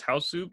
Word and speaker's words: Hausu: I think we generally pronounce it as Hausu, because there's another horror film from the 80s in America called House.